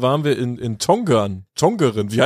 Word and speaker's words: Tongeren: Die, es gibt beide Schreibweisen da irgendwie und so ganz haben wir nicht aufgedeckt, wann man welche nutzt, aber ich würde jetzt einfach mal äh, Niederländisch Tongeren aussprechen.